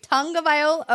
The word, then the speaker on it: Tagovailoa: When I interviewed him at the Super Bowl, he corrected me on how to pronounce his name, which I appreciated, though. I was like, okay, wait, Tagovailoa? Okay. – Yes.